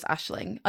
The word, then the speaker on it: Aisling: I looked up how to say her name and I'm pretty sure it's just Aisling. I know, and it's spelled A-S-H-L-I-N-G. It's not like Eiling or... – sometimes it's silent, so it'd just be like Ashley. – Okay. – But as a non-Irish speaking person, it's okay if you pronounce the G.